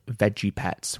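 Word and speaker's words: Vegepets: website called Veggie Pets, which is spelled V-E-G-E Pets.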